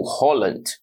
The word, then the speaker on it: Haaland: Falaremos de Erling Braut Haaland ou Holland, como a pronúncia original pede, mas a gente vai aqui dar uma brasileirada e falar Haaland mesmo.